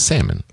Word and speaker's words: salmon: And when I hear, you used to mention salmon, which is salmon. – Right. – The L is silent.